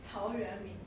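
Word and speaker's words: Tao Yuan Ming (陶渊明): It's a Chinese poet whose name is something like Dao Yuan Ming. So, anyone know? Yes, say it right. – Tao Yuan Ming.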